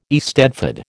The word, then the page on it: eisteddfod: American English